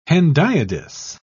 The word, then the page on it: hendiadys: British English